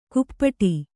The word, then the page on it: ಕುಪ್ಪಟಿ: kannada